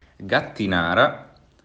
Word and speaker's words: Gattinara: E as regiões que eu vou comentar são Gatinara, Gatinara e Gemi. Olha pronunciando essas palavras. – Gattinara, Gemme, Sesia e Sori.